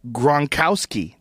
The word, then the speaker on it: Gronkowski: like Patriots tight end Rob Gronkowski.